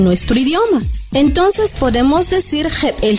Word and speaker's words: jebel: Shot se escribe X-O-T. Y al referirnos a la palabra mágica, como ya les expliqué, para nosotros es algo maravilloso, es Jebel. Jebel se escribe J E, B, glotal, E y L.